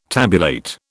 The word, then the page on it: tabulate: British English